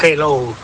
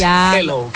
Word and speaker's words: hello: pronuncie la J, que no es hello, que es hello, que la aspire la J y no pronuncie la J potente castellana.